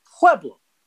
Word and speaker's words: Pueblo: You look at it, you say, Pueblo, Pueblo, Pueblo, to the point that we stopped saying Pueblo and being like a bunch of dumb Northeasterners over here. We said Pueblo, said it correctly.